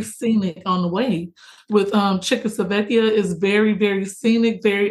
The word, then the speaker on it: Civitavecchia: The ship ports out of, and I'm going to butcher it, Civitavecchia. – Civitavecchia.